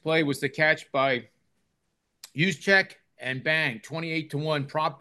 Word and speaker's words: Juszczyk: Not Juzik, but Juszczyk, the fullback for the 49ers.